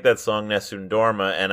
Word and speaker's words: Nessun Dorma: So the name is Nessun Dorma.